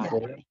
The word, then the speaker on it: Euphoria: Oh my Euphoria? – Yeah.